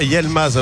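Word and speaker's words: Yılmaz: Est-ce qu'on ne doit pas dire Yelmaz alors, puisque le I se prononce E. Donc exact. – On a Y-I, donc c'est Yelmaz. – Yelmaz. – Yelmaz, pas Yel.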